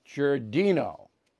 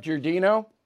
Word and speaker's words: Giardino: I, yeah, So, anyway, let's bring in uh, Sheriff Richard uh, Giordano. Giardino. Am I saying that right, Sheriff Giardino? – Giardino, thanks for having me.